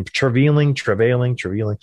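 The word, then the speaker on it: Treliving: He's got a weird pronunciation of his last name. Treveling.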